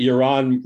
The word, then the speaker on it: Yaron: You notice that Yaron rhymes with moron.